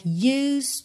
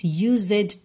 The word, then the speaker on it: used: Atenção à pronúncia, get used to, você não diz used to. Tá? Temos um E aí que vai ser completamente mudo, então você vai do S direto para o D, get used to, se acostumar.